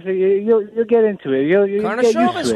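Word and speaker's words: Karnišovas: Stick to Arturas, but the last name it's pronounced Carnishovas. You announce it at the O. Carnishovas.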